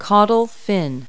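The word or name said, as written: caudal fin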